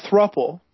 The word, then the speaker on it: thruple: It's like couple. – Okay. – Spell it like couple, but instead of a C at the beginning, you put a THR. So it's a thruple.